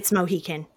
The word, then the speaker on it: Mohican: There's Mohican and Mohican. It's Mohican, but I'm Southern and I mispronounce shit all the time. So it is the Mohican River.